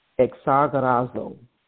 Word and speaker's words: exagorazo: So redeem comes from a Greek word, and it is pronounced exagorazo. exagerazo, meaning to rescue from loss, right?